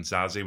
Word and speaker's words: Zazie: Well, I know you've been excited about this because I know you've mentioned Gillian with a hard G and Zazie with a hard Z